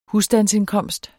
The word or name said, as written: husstandsindkomst